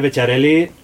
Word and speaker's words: Bechiarelli: Porque Becharelli no va a decir nadie. ¿Me entiendes? Becharelli no. O sea, un apellido como Maggi se convierte en Maggi. Tú eres Maggi, ¿no? Entonces, Becharelli se dice así.